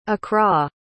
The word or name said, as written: accra